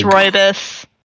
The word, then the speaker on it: rooibos: I just tried the apple cider rubios tea. I know I'm probably mispronouncing that, but it's rooibos.